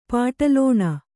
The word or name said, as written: ಪಾಟಲೋಣ